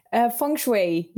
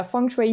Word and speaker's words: Feng Shui: Now, is that how you say it? How do you pronounce it? – Feng Shui. Yeah, Feng Shui. You pronounce it very well.